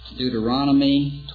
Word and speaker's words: Deuteronomy: Ruth is here, and I used to pronounce Deuteronomy, you know, like hominy, and she got angry about that. So I try to pronounce it right now, Ruth.